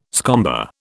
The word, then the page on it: scomber: British English